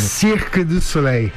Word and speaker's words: Cirque du Soleil: Por favor, como é que, qual que é a pronúncia correta em francês, você que esteve lá durante muito tempo? Fala pertinho do microfone. – Cirque du Soleil.